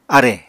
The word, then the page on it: árè: Apatani